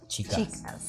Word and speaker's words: Chicas: Chicas.